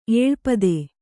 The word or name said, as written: ಏಳ್ಪದೆ